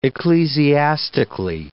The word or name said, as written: ecclesiastically